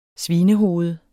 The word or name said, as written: svinehoved